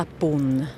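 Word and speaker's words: punt: La ponte, on écrit, mais on dit seulement la pône. – Ok, on prononce pas le T.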